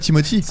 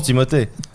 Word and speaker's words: Timothée: En plus c'est Timothée, il s'appelle Timothée, il s'appelle pas Timothy. – Ah ouais. – Bah oui, on prononce Timothée.